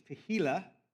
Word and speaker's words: tehillah: Um, one of the words, Hebrew words for worship is tehillah, and it refers to the spontaneous praise or the new song. One way to remember this is it rhymes with tequila, and that makes you say spontaneous things.